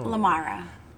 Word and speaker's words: LaMarra: My name is Shamone LaMarra. Yeah, I was named after Michael Jackson. I thought you wasn't with your intro. – Pronounce it again. – What? My name is Shamone. – Oh. – LaMarra.